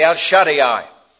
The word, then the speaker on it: El Shaddai: the El Shaddai. I suppose you want me to spell that for you. All right, E-L. S-H-A-D-D-A-I, double D-A-I. E-L-S-H-A-D-D-A-I, El Shaddai.